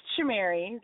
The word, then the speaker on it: Shamari: It's just Shamari, like Shakira and Madonna.